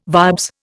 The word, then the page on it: vibes: Indian